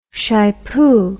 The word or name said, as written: shypoo